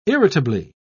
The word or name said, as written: irritably